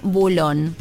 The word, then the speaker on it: Boulogne: Otras localidades que se pronuncian diferente, Boulogne, Boulón. Sí.